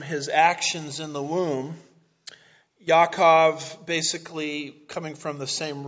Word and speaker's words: Yaakov: So his name was called Jacob, I think is pronounced Yaakov in Hebrew, second son.